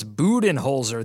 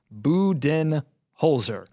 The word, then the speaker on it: Budenholzer: It is official. It's Budenholzer. This is from the Hawks game notes, B-O-O, Budenholzer.